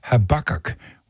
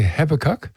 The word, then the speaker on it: Habakkuk: The Americans pronounce the name of the Old Testament book Habakkuk. We say Habakkuk, but the other two books, most of us have the same pronunciation, Job and Revelation.